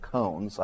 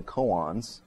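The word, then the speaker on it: koan: Zen Buddhism, it's famous for its what's often called cones. I like that pronunciation, but the hoity-toity call them koans.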